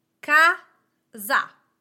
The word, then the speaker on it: casa: Let's take the word casa. Casa has two syllables. Ca-za. Ca-za. My accent goes on the first vowel, the first syllable. Ca-za. The word sono.